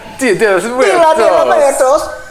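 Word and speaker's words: Día de los Muertos: So, Dia de los Muertos is how you say it.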